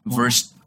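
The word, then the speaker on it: Wurst: Yun yung pronunciation sa... – W nila. – U-R-S-T. Verst. – Verst.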